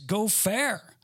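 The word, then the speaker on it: gopher (גֹּפֶר): The actual Hebrew word, believe it or not, is gopher. That's how you pronounce it.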